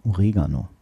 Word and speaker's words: Oregano: Während die meisten Oregano sagen, liegt die Betonung eigentlich auf dem E. Oregano.